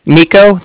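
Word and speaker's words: Niko: Nico. That's N-I-K-O.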